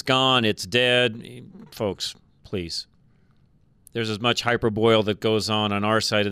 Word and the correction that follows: hyperbole: of the fence as there is, or how do you say that, Charlie? Hyperbole, sorry, I need to say that right.